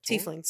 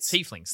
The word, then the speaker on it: Tieflings: I had that feeling when I think, is it the, I'm going to pronounce the name wrong, but the horn people, the Tieflings. – Tieflings, thank you.